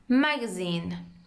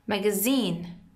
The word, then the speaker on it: magazine: In American we pronounce it magazine. And in British we say magazine. در واقع استرس با هم متفاوته.